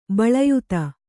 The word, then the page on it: ಬಿಲ್ಲಾಯ್ತ: kannada